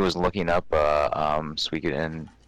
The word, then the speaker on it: Suikoden: Suikoden 2. – Oh, okay. – Suikoden. Yeah, sorry. Everybody pronounces it different.